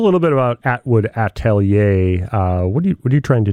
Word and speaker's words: atelier: Well, I appreciate you pronouncing it correctly. So atelier is basically, like, it's a French term for, like, a workshop.